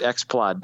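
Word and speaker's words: Xplod: Sony Explode speakers from X-Plod. – 1997. – You gotta say it right. Say it right. X-Plod, dude.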